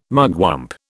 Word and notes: mugwump: British English

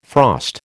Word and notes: frost: American English

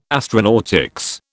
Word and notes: astronautics: British English

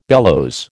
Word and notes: bellows: American English

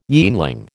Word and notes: yeanling: American English

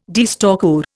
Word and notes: deerstalker: Indian

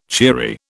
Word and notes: cheery: British English

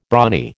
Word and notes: brawny: American English

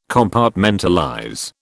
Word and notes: Compartmentalize: British English

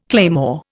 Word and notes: claymore: Indian

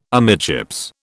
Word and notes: amidships: British English

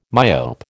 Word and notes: myope: American English

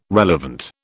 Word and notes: relevant: British English

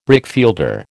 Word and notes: brickfielder: American English

